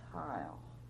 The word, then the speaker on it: Heuil: Niles Heil, H-E-U-I-L.